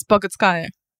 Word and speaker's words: Bogutskaya: Is it Bogutska? – It's Bogutskaya.